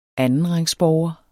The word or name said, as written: andenrangsborger